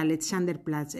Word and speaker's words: Alexanderplatz: Alexanderplatz.